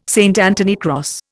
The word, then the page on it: St Anthony cross: Indian